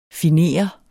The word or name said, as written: finer